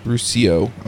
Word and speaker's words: Ruscio: Did I say that right? Uh, close, yeah, Ruscio. Ruscio, okay. Yep. It looks Italian, and so my brain just kicked over to that pronunciation. – Yeah, it's six letters, but three syllables, phonemes. Packs yeah.